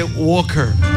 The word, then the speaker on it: Walker: Realmente se escribe Walker, pero la L no, se, no suena en inglés y dice, se dice Walker.